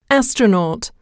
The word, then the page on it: astronaut: [ǽstrənɔ́ːt]